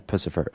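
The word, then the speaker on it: Puscifer: Monday, February 13th, it's Pussifer. Am I pronouncing his name right? Yeah, Pussifer.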